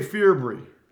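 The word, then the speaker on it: Fearbry: I would say Ferbree. It's F-E-A-R-B-R-Y, right? So I'm going fear. If it's F-E-A-R, I'm going Ferbree, B-R-Y.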